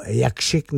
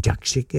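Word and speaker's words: Jakszyk: A pak jsou tam jména, který možná nejsou zas tak známá, Pat Mastelotto, Gavin Harrison, Jaco Jakšik, nebo Jakšik, jak se taky vyslovuje, Jeremy Stacy.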